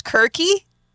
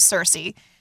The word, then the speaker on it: Circe: Her name is technically pronounced Kirky. The Greek pronunciation is Kirky, but everybody knows it as Circe.